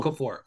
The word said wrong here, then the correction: Okorafor: Sorry, oh, Chukwuma Okorafor.